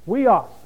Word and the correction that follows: Huios: Actually, it's pronounced hui with an H-W-E-E. Huios.